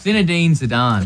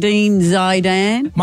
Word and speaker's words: Zinedine Zidane: It's Zinedine Zidane. – Close Zinedine enough. Zidane. – Zinedine Zidane.